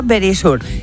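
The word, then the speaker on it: Verisure: Securitas Direct es ahora Very Sure. Eso aprendido, yo creo que ya lo sabéis. Very pero sure. Very Sure, ay, que me lo dice, eso es. Bueno... Very sure. – Ahí lo digo bien.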